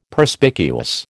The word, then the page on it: Perspicuous: American English